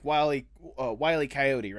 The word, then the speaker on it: Wile E. Coyote: It is like Wile E. Coyote, right?